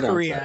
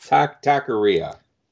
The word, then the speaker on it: Taqueria: How would I pronounce that? – Taqueria, I believe. – Taqueria.